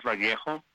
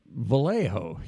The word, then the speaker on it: Vallejo: it's Vallejo, you know, we're pronouncing the L's, you know, and you go, well, no, it could be Vallejo.